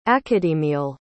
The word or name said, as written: academial